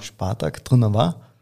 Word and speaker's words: Spartak Trnava: Das ist richtig ausgesprochen. Spartak, Spartak Trnava, genau. – Spartak Trnava.